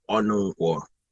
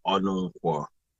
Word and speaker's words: Onunkwo: Okay, my name is Chukwuemeka Onunkwo. It's usually a struggle to say Onunkwo, but that's the surname, Onunkwo.